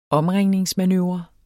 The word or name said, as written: OMRINGNING